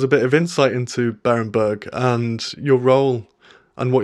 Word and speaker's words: Berenberg: I hope you pronounced Berenberg right. – Hi. Yes, thanks for having me on. You said it exactly right. It is Berenberg.